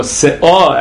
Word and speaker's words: se'ah: The brisa says sa'ah, or se'ah, actually, if you pronounce it correctly, which is a certain volume, which is the equivalent of six kamin, truma.